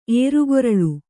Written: ಏರುಗೊರಳು

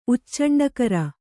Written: ಉಚ್ಚಂಡಕರ

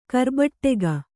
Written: ಕಾರ್ಬಟ್ಟೆಗ

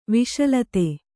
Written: ವಿಷಲತೆ

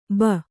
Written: ಭೋ

